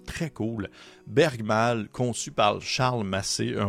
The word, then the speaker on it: Bergmal: Bergmal, qui est prononcé, ah non, il est marqué plutôt prononcé Bergmol.